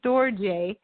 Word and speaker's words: storge: Another kind of, there's another kind of love, and it's called storge, but it's spelled storge, but it's storge.